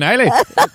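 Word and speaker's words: Islay: This is a product of the, how do we pronounce it? – Isla. I believe it's Isla.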